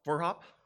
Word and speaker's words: Vroegop: But here's his last name, Vroegop. How do you spell that? How do you pronounce that, right? Verhop, okay. German, I think it is.